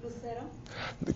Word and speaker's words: Lucero: Lucero. – Can you spell that for me? – Sure. L-U-C-E-R-O.